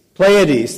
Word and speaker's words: Pleiades: Pleiades.